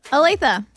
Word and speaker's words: Olathe: Olathe.